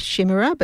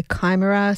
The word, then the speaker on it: Chimera: I tend to say Chimera, but some people say Chimera, some people say Chimera. Anyway, C-H-I-M-E-R-A, also from 2024.